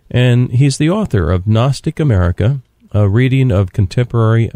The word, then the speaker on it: Gnostic: But just when you hear the word Gnostic America, it's spelled G-N-O-S-T-I-C, kind of like agnostic without the A.